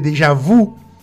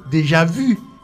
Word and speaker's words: déjà vu: Vous, V-O-U-S. Vous. Entonces, por eso, la gente que dice déjà vous. Ok. Déjà vous, es muy, muy mal, porque no puede decir déjà et usted. Tiene que decir déjà vu, vu.